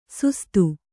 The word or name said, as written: ಸುಸ್ತು